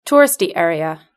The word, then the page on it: touristy area: British English